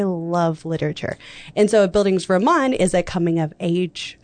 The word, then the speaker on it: Bildungsroman: Bildungsroman.